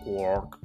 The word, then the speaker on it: quark: Sí, de hecho es la pronunciación correcta, entre comillas, porque para hacer la rima bien, ¿no? Porque es three quarks for Mr. Mark, ¿no? Y Mark sí que sabemos cómo se pronuncia. – Sí. – Entonces, pues, como tiene que rimar, pues no hay más tutía de que sea con quark, sonido quark, ¿no?